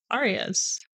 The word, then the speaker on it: Arias: Massy Arias? – Arias? – Arias. I'm sorry, Massy.